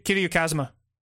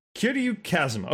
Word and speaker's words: Kiryu Kazuma: So what is it, how do I pronounce this? I don't want to butcher it. – Kiryu Kazuma. – Kiryu Kazuma. Okay.